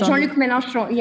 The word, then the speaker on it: Mélenchon: I think you're referring to Jean Mélenchon. – Yes, Jean-Luc Mélenchon, yes. – Congratulations for pronouncing his name.